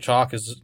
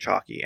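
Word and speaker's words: Chalke: Sarah Chalk she's a great actress, but. – I just found out it's Chalk, and I've always pronounced it Chalky, and I just found out that it was Chalk.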